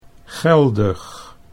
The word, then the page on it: geldig: Dutch